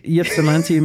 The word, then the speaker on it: Ypsilanti: I'm probably going to get it wrong as well, but Yips, I think it's Ypsilanti. – Ypsilanti, Michigan. Yeah, Michigan is known for its amazing lake names and town names.